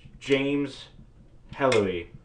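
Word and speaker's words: Herlihy: James Hellery. I believe I've been saying that right. I listened to his name like 25 times during the book. I forgot. It's a H-E-R-L-I-H-Y.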